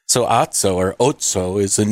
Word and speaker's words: Otso: How do you say this? – I'd say Otso. – Yeah. O-T-S-O.